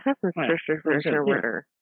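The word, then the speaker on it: Hafnarfjörður: It's Hafnarfjordur. – Yeah. Hafnarfjordur. – Yeah, Hafnarfjordur.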